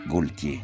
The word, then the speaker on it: Gaultier: No, quello è Jean-Paul Gaultier, con la L, Gaultier. – Ma non si dice Gaultier.